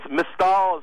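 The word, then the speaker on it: Mystal: It's Mistal. It rhymes with Crystal.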